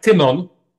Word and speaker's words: Tymon: So, first name would be more like Tymon.